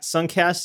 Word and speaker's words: Sunkast: You can follow me on Twitter at Suncast. That's spelled S-U-N-K-A-S-T.